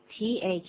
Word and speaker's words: thirsty: thirsty thirsty。 Thirsty 的发音要特别注意什么？就是它开头的音 s 是拼成 th 的这个音，舌头要放在牙齿的当中，然后再吹气，这样子才正确。